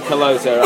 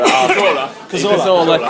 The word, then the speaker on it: Cazorla: I've got two: Danny Murphy for Blackburn and Santi Colosa. – Cazorla.